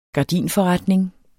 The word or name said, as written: gardinforretning